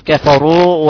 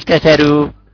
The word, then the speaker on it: كفروا: طيب نقول في قوله تعالى إن الذين كفروا، نقول كفروا ولا نقول كفروا، كفروا، برضو هاي من الأخطاء الشائعة في النطق في الراء المضمومة، الراء المضمومة مظلومة، الناس كثروا صبروا، ورش نفسه ما برقق هذا الراء اللي في صبروا، لا يقول صبروا ولا يقول كثروا.